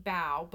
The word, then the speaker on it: bao: which are B-A-O, pronounced bao.